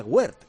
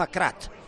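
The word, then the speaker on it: Aguerd: Y no se pronuncia Wert. Dice que se pronuncia Nayef Akrat.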